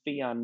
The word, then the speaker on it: Fijan: My last name rhymes with neon, Fijan, neon.